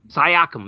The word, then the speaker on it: Siakam: Pascal I, Siakam. Siakam. Is that how you say it? Pascal Siakam. Siakam, that's it. That's Siakam.